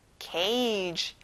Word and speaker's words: cage: Cage. 여기는 soft G 발음이 나서 G 소리가 안 나요.